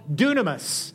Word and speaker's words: dynamis: However, when we're baptized in the Holy Spirit, again, the second experience in our journey, we receive what's called dunamis. Now, it's not spelled dunamis. It's spelled dynamis. It's where we get the word dynamite.